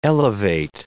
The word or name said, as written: elevate